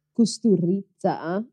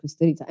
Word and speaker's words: Kusturica: Uh, to learn a bit more, we're talking about 1995's Underground, written and directed by I think it's Amir Costa Costa Rica. – Kusturica. – Okay. Uh, I don't know how to pronounce it in his language. We call it Kusturica.